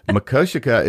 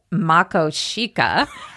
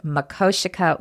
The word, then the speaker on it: Makoshika: Makoshika is how you pronounce it. It isn't how we pronounced it for a couple months before we got there. – Right. It looks like, when you look at the word, it looks like it would be pronounced Makoshika, which is what we called it for months. But it is Makoshika, which is an Indian word for badlands.